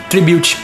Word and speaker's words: Tribute: Acho que assim se pronuncia, Tributo. – Tribute. – Ah, então é assim? – Faz o CCAA que você aprende.